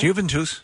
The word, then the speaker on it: Juventus: No, it's Juventus. – No, I know what it says, but that's not how it's pronounced. – Okay. – Unless I'm wrong, and listen, I'm wrong most of the time, but Juventus, I believe, is an Italian club.